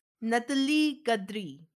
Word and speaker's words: Natalis Gaudry: But pronounce it like you're making fun of it in French, and that usually works out, too. – Natalie Gaudry.